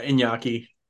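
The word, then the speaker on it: Iñaki: Is it Inyaki? – Yeah, Inyaki.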